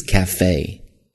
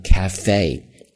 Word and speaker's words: cafe: 因为后面的 only, 第一个音是一个元音发音开始，所以呢前面这个定冠词读 V, the only trouble, O, 双元音嘛 ,with this cafe, 注意啊读 cafe, cafe, 这么去读 ,with this, 这儿两个 th 都是一个浊音咬舌头。